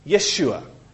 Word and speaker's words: Yeshua: Yeshua was the name, as they would probably have pronounced it.